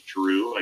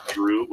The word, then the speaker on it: DRU: My name's DRU. I go by the artist name DRU, but spell it capital D, capital R, capital U.